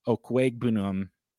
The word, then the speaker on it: Okwuegbunam: Alberto Okwuegbunam.